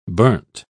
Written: burnt

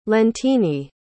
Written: leontine

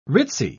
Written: ritzy

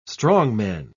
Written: strongman

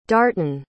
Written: darton

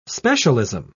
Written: specialism

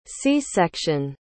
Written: c-section

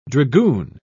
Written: dragoon

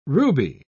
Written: ruby

